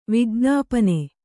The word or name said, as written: ವಿಜ್ಞಾಪನೆ